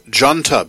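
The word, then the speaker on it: Déanta: The Irish group Janta before that name is spelled D-E-A-N-T-A, from their debut album Ready for the Storm.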